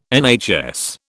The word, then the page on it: NHS: British English